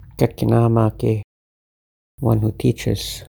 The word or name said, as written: Gakinaamaage